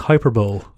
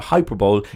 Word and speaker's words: hyperbole: I think that's pronounced hyperbole. – It's a hyperbole.